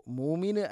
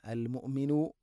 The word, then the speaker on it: المؤمن: المؤمنُ مؤمن അല്ല المؤمنُ. ഹംസയുടെ ഉച്ചാരണം കൃത്യമാക്കണം. തൊണ്ടയുടെ താഴെ ഭാഗമാണ്, എന്നാൽ ഖൽഖല വരാൻ പാടില്ല. المؤمنُ അല്ല المؤمنُ. മുഅ്മിനു എന്ന് ഹംസ തീരെ ഉച്ചരിക്കാതിരിക്കാനും പാടില്ല.